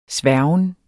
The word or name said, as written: sværgen